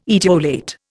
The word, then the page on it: etiolate: Indian